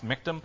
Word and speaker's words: miktam: That's just a transliteration of the Hebrew word into English letters. It literally is pronounced miktam.